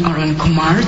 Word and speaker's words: Arunkumar: I'm Dr. Pani Arunkumar. The first name is spelled P-O-N-N-I. The last name is spelled A-R-U-N-K-U-M-A-R.